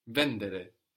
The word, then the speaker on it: vendere: So some Italian verbs have the accent third from the end, vendere, but only a few. Mostly we have the accent on the penultimate syllable, on the second from the end, no, in the tu form.